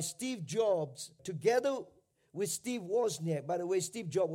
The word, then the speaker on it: Wozniak: It's about Steve Jobs, whatever, and his partner Steve, I can't really pronounce that name, but it's Wozniak. Wozniak.